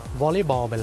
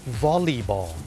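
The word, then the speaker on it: volleyball: วอลเลย์บอลไปแล้วเนี่ยคนไทยเนี่ยเมื่อพูดถึงกีฬาวอลเลย์บอลเนี่ยออกเสียงว่าวอลเลย์ถูกไหมครับวอลเลย์บอลแต่จริงๆแล้วนะครับเจ้าของภาษาเนี่ยไม่ได้ออกเสียงว่าวอลเลย์บอลนะครับเขาออกเสียงว่าวอลลีบอลวอลลีบอลตัว LEY เป็นออกเสียงลี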